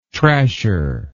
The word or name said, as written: trasher